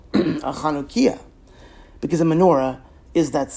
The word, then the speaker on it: Chanukiah: But instead, we should be singing, O Hanukkah, O Hanukkah, come light the Chanukiah, with the emphasis on the first syllable, so the song still works.